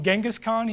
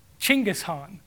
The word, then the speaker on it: Chinggis Khan: And you look through your money and it's all got the picture of, you know, a lot of it's got, we call them Genghis Khan here. You know how they say it? Chinggis Khan.